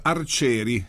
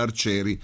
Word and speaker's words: Archeri: Si chiamano Clatrus Arcieri. Si pronuncia così, ma si scrive in realtà Clatrus col TH e Arcieri si scrive Archeri. Ok, ma bene. – Si pronuncia Arcieri.